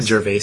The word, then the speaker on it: Gervais: Am I saying his name right? – Gervais? – Ricky? Huh? – Gervais? – Ricky Gervais? – Ricky Gervais. – Ricky Gervais. – Is his name Gervais? – Gervais, yeah.